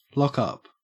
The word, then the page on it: lockup: Australian English